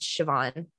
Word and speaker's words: Siobhan: I'm pretty sure it's pronounced Siobhan. It's the Irish where it yes. has like weird consonants in the middle.